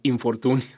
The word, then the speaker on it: infortunio: Pero nosotros cuando lo anunciamos en nuestros programas ponemos in-fortunio para que se entienda como en fortuna, pero se pronuncia infortunio.